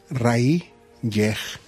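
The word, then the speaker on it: R'lyeh: aquí va la pronunciación más cercana, es Rayyeh.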